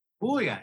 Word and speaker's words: Puglia: From Puglia. Is that how you say it? – Puglia. Exactamundo.